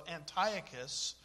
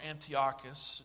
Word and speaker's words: Antiochus: So Antiochus, or Antiochus, it just depends on which syllable gets the emphasis, as one of my professors used to say, went into the Jewish temple.